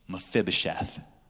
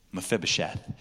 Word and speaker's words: Mephibosheth: And we're going to go to the hero called, I'm going to slow down because it's a mouthful to get out, Mephibosheth. Yeah, I said that right. That was English for me, Mephibosheth.